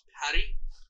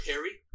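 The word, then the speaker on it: Pari (پری): in Persian, it would be pronounced Pari or Peri.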